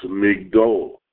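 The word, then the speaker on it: Migdol (מגדול): Nevertheless, in our verse, it is pronounced Magdil, while in Samuel, it is changed altogether and pronounced Migdol.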